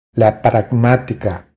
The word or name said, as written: La pragmatica